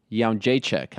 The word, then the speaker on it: Jędrzejczyk: Instead, Joanna Jędrzejczyk.